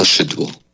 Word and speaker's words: Szydło: I'm just going to say Szydło, S-Z-Y-D-L-O. Let's listen. Here's her name. Hopefully. – Beata Szydło. – Okay, let's see here. Try again. Here we go. – Beata Szydło.